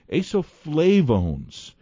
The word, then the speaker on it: isoflavones: Isoflavones, which come from legumes, and you mentioned soybeans. Tell us about and it's I S O F L A V O N E S, pronounced isoflavones. Talk to us about those.